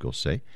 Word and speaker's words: Gossec: Last name is spelled G-O-S-S-E-C. One might think it's Gossec, but he apparently pronounced it Gosset.